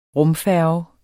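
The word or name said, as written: RUMFÆRGEN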